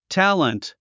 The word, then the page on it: talent: tǽlənt; タレント